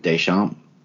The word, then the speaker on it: Deschamps: Jett Ducharme. Yes. Ducharme. I don't know how to pronounce it. Deschamps. Jett Deschamps.